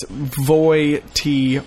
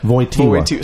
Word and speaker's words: Wojtyla: It's Wojtyla. – Wojtyla.